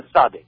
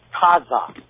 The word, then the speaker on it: Tzadik: the 18th character is the Tzadik, right? And this UPKers, proponents of Lashon Kodash, they claim that's pronounced Taza, has two syllables, the pronunciation, Taza.